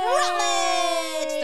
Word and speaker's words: Rutledge: Rutledge!